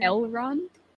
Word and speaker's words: Elrond: Elrond.